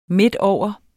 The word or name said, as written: midtover